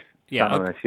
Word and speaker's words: San Mamés: What kind of game awaits United, good or otherwise, at San Mamés? Is that how you say it, first of all? And is it a very atmospheric... – San Mamés, yeah.